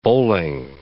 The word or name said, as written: bowling